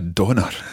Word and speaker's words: dander: And this means to go for a wander, to go for a walk, or to go for a donner, as we say in Scottish. – A donner. – A donner. A dander is spelt dander.